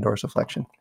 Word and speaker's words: dorsiflexion: There's lots of stability there if you're in dorsiflexion.